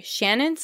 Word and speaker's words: Ciannon: All right, so my next pick today is Witches Steeped in Gold by Shannon Smart. Shannon is spelled C-I-A-N-N-O-N, for those of you that are trying to look it up.